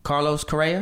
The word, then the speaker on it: Carlos Correa: Another piece of the story though was after winning the title, Carlos Correa.